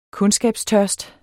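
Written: kundskabstørst

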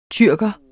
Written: tyrker

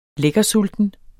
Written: lækkersulten

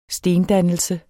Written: stendannelse

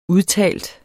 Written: udtalt

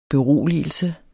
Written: beroligelse

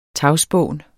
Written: tagspån

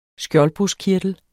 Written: SKJOLDBRUSKKIRTEL

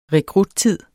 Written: rekruttid

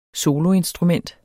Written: soloinstrument

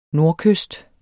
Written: nordkyst

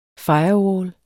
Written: firewall

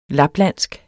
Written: laplandsk

